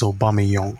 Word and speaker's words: Aubameyang: First of all, the correct pronunciation of his name is Aubameyang.